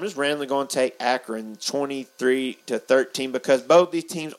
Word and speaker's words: Akron: But we got how do you pronounce this one, Slayton? – Akron. – I knew I was saying it wrong.